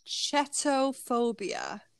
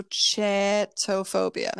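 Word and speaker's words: chaetophobia: But I think it's chetophobia. Chetophobia. – Chetophobia. Is it spelt like CH? – C-H-A-E-T-O-phobia. – Like chat. – Mm-hmm. Chat, but then there's an E behind it. So chetophobia.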